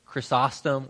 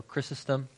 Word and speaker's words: Chrysostom: So like I did my dissertation on John Chrysostom or Chrysostom. So again, about every figure in early church, there's two ways to say it.